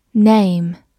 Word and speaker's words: name: Name, n a m e。 Name 字母 a 发它本身的音 a，末尾的 e 不发音。Name，它是名词，表示姓名或者名声、名气。